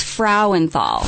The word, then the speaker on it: Frauenthal: So it's Frauenthal. – Frau. – So it's German. Frau. Frauenthal. – Frauenthal. – Yes.